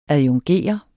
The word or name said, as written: adjungere